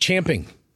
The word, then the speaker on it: champing: Chomping at the bit. – Champing. It's champing. – Okay, sure. – It's champing. – It's spelled champing. It's pronounced chomping.